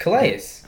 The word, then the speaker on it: Calais: I forgot how to say his name. – Calais Campbell. – Calais. – It's Calais.